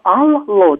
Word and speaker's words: алло: Пишется слово алло, два л. А произносится мягко, алло. Вот да. это грамотное, правильное произношение.